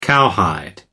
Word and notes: cowhide: American English

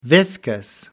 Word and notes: viscous: American English

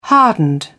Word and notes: hardened: British English

